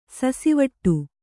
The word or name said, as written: ಸಸಿವಟ್ಟು